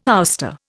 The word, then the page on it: ouster: Indian